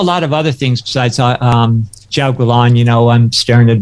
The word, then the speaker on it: Jiaogulan: Jiaogulan. – Jiao. Second word, gu, G-U. And then third word, lan, L-A-N. – L-A-N.